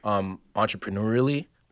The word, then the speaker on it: entrepreneurially: entrepreneurially, mostly. – That's a little tricky to say. – Did I say it right? – Yeah, you did.